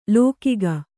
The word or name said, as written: ಲೋಕಿಗ